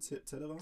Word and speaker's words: Zetterer: Meinst du, dass dieser äh, hier, dieser Michel Zetterer, oder wird der so ausgesprochen? Ja. Wie schreit ihr das im Stadion? Zetterer? – Zetterer.